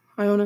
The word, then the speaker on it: Iona: UNC beat Iona. Iona, is that how you say it, Remington? – Yes.